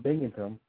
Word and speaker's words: Binghamton: Now, with the big move to Binghamton, I hope I'm saying that correctly.